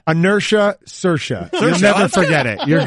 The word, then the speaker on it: Saoirse: From Saoirse Ronan. – Saoirse Ronan. – Saoirse Ronan.